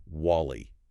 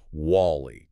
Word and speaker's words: WALL-E: He insists repeatedly on calling it WALL-E. – WALL-E? – Yes. Putting a bizarre emphasis and separation on the syllables, completely ignoring the massive marketing campaign and cultural ubiquity of the correct pronunciation, WALL-E.